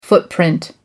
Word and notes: footprint: American English

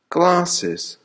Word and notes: glasses: American English

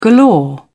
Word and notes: galore: British English

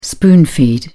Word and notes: spoon feed: British English

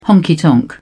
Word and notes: honky tonk: British English

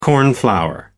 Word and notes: cornflower: American English

